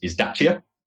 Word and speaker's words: Dacia: One of the things with only being 10 years old in the UK, so the correct pronunciation of our brand is Dacia.